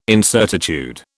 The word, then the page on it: incertitude: British English